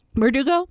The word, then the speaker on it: Bardugo: Shadow and Bone is written by Leigh Bargato, I believe is how you pronounce her last name. – Bardugo.